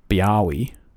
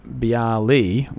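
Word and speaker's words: Biały: But according to a Redditor whose name is Jemia, that is actually the correct pronunciation, because with Polish diacritics, I've never heard of that term, it would be written Bialy with the little strike through the L, and the L with the strike through it is pronounced like the English W. So it actually is Biawi.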